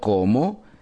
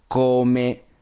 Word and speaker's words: come: En italiano, ricordati, acuérdate que es come. Termina con la E, no con la O. Entonces, no como, y se dice en cambio, come, justamente como si tuvieras que decir come en el sentido de comer, ¿ok?